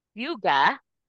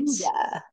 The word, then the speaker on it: hygge: But yuga. okay. Yuga. Let me spell it for you because this is this is a Danish word. It's H Y G G E. Okay. And but it's pronounced yuga.